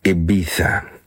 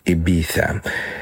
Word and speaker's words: Ibiza: Spanish pronunciation is Ibiza, Ibiza. But anglicized, the British pronunciation is Ibiza. But Americans will say Ibiza.